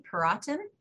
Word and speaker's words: Perotin: Her name is Maggie Perotin.